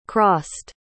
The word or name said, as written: crossed